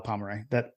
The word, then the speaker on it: Pomeroy: In the second half of the in the second half with the score tied 0-0, a Georgia back, I'm going to butcher the name, Jason Pomeroy. Do you want to check in? Yeah, that sounds right. I'll allow Pomeroy. That's how I would say it.